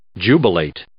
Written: jubilate

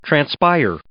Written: transpire

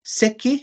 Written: sekij

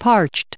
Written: parched

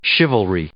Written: chivalry

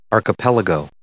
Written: archipelago

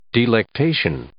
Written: delectation

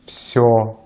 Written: всё

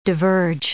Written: diverge

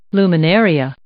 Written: luminaria

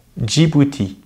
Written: Djibouti

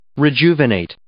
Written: rejuvenate

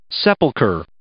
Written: sepulchre

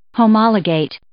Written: homologate

